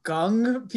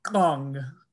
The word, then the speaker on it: !Kung: And I may be messing this up, but I think it's pronounced the Gung people, where you kind of and it is spelled exclamation point K-U-N-G.